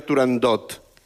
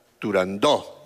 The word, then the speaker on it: Turandot: No se debe pronunciar Turandot. – Ah, no, Turandó. – La ópera se titula Turandó.